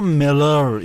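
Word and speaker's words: Müller: مولر بود دیگه، بعد ایشون یک اصراری داشت که این مولر رو ملر اینجوری تلفظ می‌کنه و اون موقع عصر و اعصاب بود.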